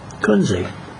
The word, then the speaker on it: Kunze: Last name is spelled K-U-N-Z-E. Kunze.